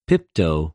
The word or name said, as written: πιπτω